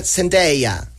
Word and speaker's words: Zendaya: Y que se pronuncia Zendaya. Bueno, Zendaya. – Si lo dices así, Zendaya. Pero aquí todos dicen Zendaya.